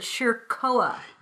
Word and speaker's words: Schirkoa: Shirkoa. – Yeah. – I'm going to spell it. S-C-H-I-R-K-O-A.